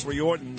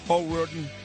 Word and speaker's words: O'Riordan: Once again, a very happy birthday here to my friend Dolores Riordan, the lead singer here of the Cranberries. How do you say it? – O'Riordan.